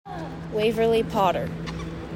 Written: WAVERLY POTTER

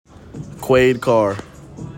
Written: QUAID CARR

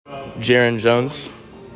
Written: JERON JONES

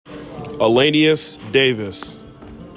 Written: ELINNEUS DAVIS

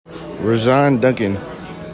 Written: RAHSJON DUNCAN

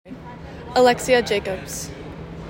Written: ALEXIA JACOBS

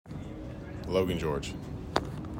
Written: LOGAN GEORGE

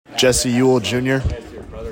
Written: JESSE EWELL JR

